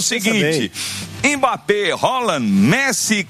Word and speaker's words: Haaland: Mbappé, Haaland, que não é Haaland, viu? Ele ensinou. Foi ele que ensinou. Ele falou: a pronúncia do meu nome é Haaland. É Haaland, Messi, Cristiano Ronaldo.